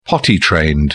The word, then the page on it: potty-trained: British English